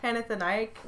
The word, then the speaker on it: Panathenaic: Panathenaic. – Yep. – Am I saying that right? – Yep. – Panathenaic ritual.